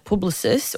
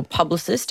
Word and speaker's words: Publicis: He's the CEO and he has recently sold his wonderful independent business to the likes of the lovely French people at Publicis or Publicis, depending on how you want to pronounce it.